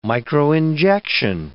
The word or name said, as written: microinjection